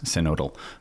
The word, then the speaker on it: synodal: which is how you pronounce synodal, because it's a single, it's a vowel followed by a single consonant and then another vowel. So that makes the O.